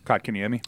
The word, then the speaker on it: Kotkaniemi: Kotkaniemi.